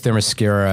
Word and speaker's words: Themyscira: Themyscira, I think is that how you pronounce yes.